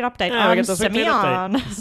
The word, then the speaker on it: Semillon: So yes, the grape of the week thrones is Semyon.